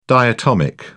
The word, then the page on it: diatomic: British English